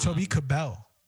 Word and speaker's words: Kebbell: It's Toby Kebbell. – Is it really Toby Kebbell? – Bro, I don't know. – I thought it was Toby Kebbell.